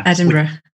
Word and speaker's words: Edinburgh: I mean, I can't write it for you guys, but Edinburgh? – Yeah. Edinburgh.